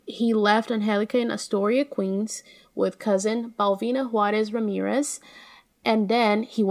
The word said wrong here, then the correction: Belvina: Is it Balvina or Belvina? Did I mispronounce it? – I think it's Belvina. – Okay, I'm sorry. It's Belvina.